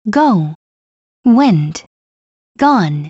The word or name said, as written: go went gone